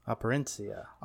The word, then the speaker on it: Operencia: Well, real quick, I seen that you've been playing, or that you played Operencia. I think I said that right. – Yes. – Operencia. – Operencia. – Close enough. How I would say it.